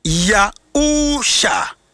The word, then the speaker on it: Yahusha: The Savior's name in modern Hebrew is spelled Yod He Wow Shin Ayin. Om the free left to right. Yod He Wow Shin Ayin. Pronounce Yahusha. Yahusha. Yahusha.